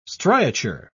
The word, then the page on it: striature: British English